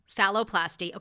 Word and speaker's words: phalloplasty: Phalloplasty.